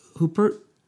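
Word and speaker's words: Huppert: Isabel Hooper.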